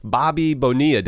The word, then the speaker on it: Bobby Bonilla: But first, man, you wanted to talk about Bobby Bonilla Day. Is that how you say it? – Yeah, that's it. That's right.